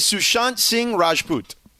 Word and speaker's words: Sushant Singh Rajput: His name is Sushant Singh Rajput.